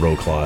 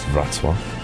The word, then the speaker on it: Wrocław: So it's like row claw. That's how everyone pronounces it, but it's Wrocław.